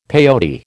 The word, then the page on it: peyote: American English